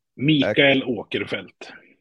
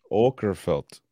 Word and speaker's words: Åkerfeldt: Say it again. Mikael Åkerfeldt. – Åkerfeldt.